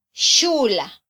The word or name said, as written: xula